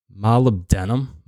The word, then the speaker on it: molybdenum: It's called molybdenum. Molybdenum. M-O-L-Y-B-D-E-N-U-M.